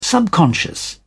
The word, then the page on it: subconscious: British English